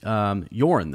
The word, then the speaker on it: Yoren: And so they meet Yoren, though.